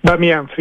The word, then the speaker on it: Bamiyan: Bamiyan, sí, perfecto.